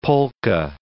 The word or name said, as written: polka